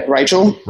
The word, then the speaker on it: Reichel: Ruth Reichel. Did I say that right? Can you spell that last name for me? – Yeah. Reichel. R-E-I-C-H-E-L.